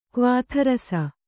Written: ಕ್ವಾಥರಸ

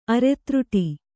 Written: ಅರೆತ್ರುಟಿ

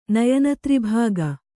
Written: ನಯನತ್ರಿಭಾಗ

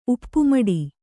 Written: ಉಪ್ಪುಮಡಿ

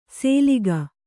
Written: ಸೇಲಿಗ

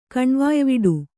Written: ಕಣ್ವಾಯ್ವಿಡು

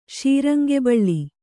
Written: ಶೀರಂಗೆಬಳ್ಳಿ

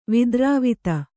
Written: ವಿದ್ರಾವಿತ

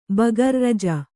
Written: ಬೇಗಾರಿ

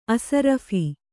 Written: ಅಷರಾಫ಼್

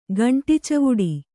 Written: ಗಂಟಿಚವುಡಿ

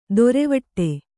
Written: ದೊರೆವಟ್ಟೆ

